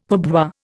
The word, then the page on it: pourboire: Indian